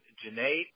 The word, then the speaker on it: Janae: My name is Janae. It rhymes with lemonade. It always helps others to see and hear the name and what it rhymes with, something that's quite common, like lemonade.